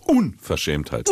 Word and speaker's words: Unverschämtheit: Immer Betonung auf Unverschämtheit. – Unverschämtheit. Immer vorne, immer vorne drauf.